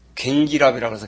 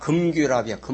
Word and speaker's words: 금괴랍: 저것도 가가지고 무슨 또 뭐 약 그냥 그 독한 약 그냥 그럼 테라마이싱을 옛날에 우리 어릴 때는 갱기랍이라고 해서 갱기랍. 갱기랍이 뭔가 하고 나중에 의학을 공부를 하면서 보니까 갱기랍이 아니라 금괴랍이야. 금괴랍.